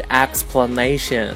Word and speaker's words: explanation: 那么大家注意一下，它的动词跟名词的这个发音还是有区别的。动词呢是 explain，而名词呢是 explanation。那么在开头的那个 e 的发音是不一样的，explanation 和 explain。